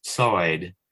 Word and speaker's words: Lloyd: That's a lateralized sibilant, linguists call it. In other words, you're pronouncing an S, but you're letting the air come out the side of your tongue instead of the front. So it's sa, a sa. So Lloyd is Soyd, and Llewellyn is Suessin.